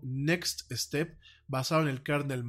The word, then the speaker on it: NeXT: En ese sentido, bueno, déjame, nada más te puntualizo que Next, esta empresa Next Computers, pues fue fundada, fue fundada en 1985 por Steve Jobs. Eh, su nombre como tal se pronuncia, se escriba next como siguiente.